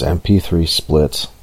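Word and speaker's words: mp3splt: The tool is MP3 split, but it is spelled MP3 SPLT.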